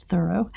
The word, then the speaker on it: Thoreau: For me, I just go more recently, 2 nights ago, we saw a play that was a solo performance on Thoreau. Mm-hmm. I guess the correct pronunciation is Thoreau.